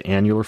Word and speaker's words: annular: these annular, is that how you pronounce it? Annular fibers? – Annular fibers. Yeah.